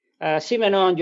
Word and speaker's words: Simenon: George Simenon. He wrote detective novels. – How do you say his last name again? – Simenon, you write S-I-M-E-N-O-N.